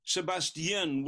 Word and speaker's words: Sebestyen: You'll correct any of my mispronunciations, since I'm not... – Sebestyen with a Y,